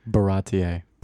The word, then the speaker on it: Baratie: Baratie. Is that how you say it? Baratie? – Baratie. – Baratie. Baratie. Okay. Baratie.